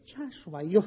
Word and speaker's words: Iosua: Nu-i Iisus, cum îl pronunțăm noi românii, nici Jesus, cum îl pronunță americanii sau Iezus, cum îl pronunță ungurii, ci este Iosua, Iosua, în felul în care noi pronunțăm Iosua.